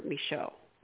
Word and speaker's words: Micheaux: a lost film of the legendary filmmaker Oscar Micheaux.